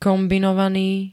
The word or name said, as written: kombinovany